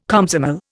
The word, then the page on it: Komsomol: Indian